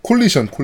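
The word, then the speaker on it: Coalition: 그니까 사실 기어즈 같은 경우에는 그 전까지는 에픽 스토 에픽에서 만들다가 이, 그 사편부터는 이제 코, 코올라이션 저거 뭐라고 발음하지? 콜리션, 콜리션. 콜리션이라고 발음하나? 네.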